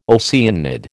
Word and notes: Oceanid: American English

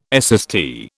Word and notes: SST: British English

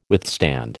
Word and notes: withstand: American English

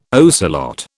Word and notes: ocelot: British English

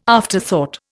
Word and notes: afterthought: Indian

